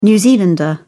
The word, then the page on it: New Zealander: British English